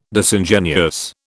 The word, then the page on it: Disingenuous: British English